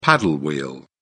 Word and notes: paddle wheel: British English

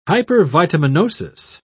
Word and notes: hypervitaminosis: British English

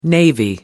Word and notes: navy blue: British English

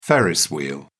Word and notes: ferris wheel: British English

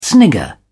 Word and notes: snigger: English; British English

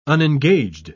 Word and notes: unengaged: British English

